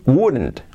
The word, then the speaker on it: would: Would, el condicional would, would, cuidado con la pronunciación, no es ni good ni would. La, la, garganta abierta, uh, uh, uh, y la boca abierta, Es como wouldn't, wouldn't.